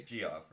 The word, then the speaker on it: Geoff: It's Geoff, right? I'm saying it right? – Oh, yeah, you're right.